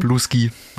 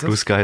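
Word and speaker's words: Bluesky: Blue Ski. – Wie spricht man es richtig? – Ist Blue Sky ist richtig.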